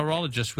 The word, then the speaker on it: pterologist: A pterologist with a silent P.